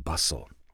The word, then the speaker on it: bustle: Now, how do you spell hustle? H-U-S-T-L-E. H-U-S-T-L-E. The T is silent. How do you spell bustle? Well, it's B-U-S-T-L-E.